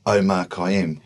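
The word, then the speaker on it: Omar Kiam: A Facebook page for Omar Khayyam, which is the name that I write under.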